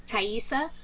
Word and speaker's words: Taissa: Taissa.